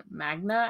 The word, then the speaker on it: manga: They would attempt to put their religious ideas into popular anime and manga at the time. How do you pronounce that? Magna themes. So they would put out their own manga. Sorry, manga.